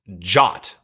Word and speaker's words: JWT: What's hilarious is in the docs, it says JWT pronounced JOT, like JOT.